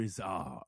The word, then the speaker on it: Izzard: People say Izzard, but it's Izzard. – God, that sounds so much cooler, too. – Izzard? – Izzard. – It is. – Mr. Izzard.